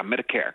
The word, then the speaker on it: Medicare: Ja, Medicare.